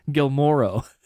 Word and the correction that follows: Guillermo: Who's Gilmore del Toro? – Yeah, it's Guillermo. – Guillermo.